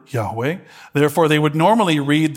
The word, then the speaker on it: Yahweh: We think it's pronounced Yahweh. It's actually not for certain because no good Jew would ever pronounce God's name.